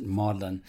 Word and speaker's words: Magdalen: I know there's a Magdalen College in Oxford, and it's spelled Magdalen, if you ever see it spelled out. But I think the local Brits, for a variety of reasons, and you could dig into that on your own, but they do pronounce it Magdalen.